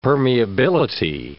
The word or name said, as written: permeability